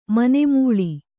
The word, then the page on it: ಮನೆಮೂಳಿ: mane mūḷi